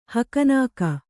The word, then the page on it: ಹಕನಾಕ: kannada; hakanāka